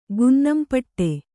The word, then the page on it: ಗುನ್ನಾಂಪಟ್ಟಿ: gunnāmpaṭṭi